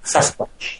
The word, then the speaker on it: Sasquatch: Sasquatch, yes.